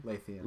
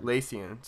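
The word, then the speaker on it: Lúthien: Is that how you say it? Lithien? – Lathien. – Lathien. Sorry, guys.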